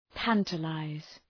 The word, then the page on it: tantalize: ‘tæntə,laız